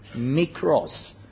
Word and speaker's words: mikros: The word, Greek word for least, is pronounced something like this, mikros, from which we find micro in English language.